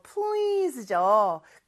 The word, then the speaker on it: please: 자, S인데 발음은 Z죠, 그쵸? 자, 그래서 please 이렇게 발음해 주셔야 되고요.